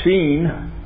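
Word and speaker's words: Sheen (שין): It's pronounced Sheen, the Sheen section. It's like our letter S.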